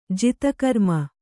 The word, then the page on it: ಜಿತಕರ್ಮ: jita karma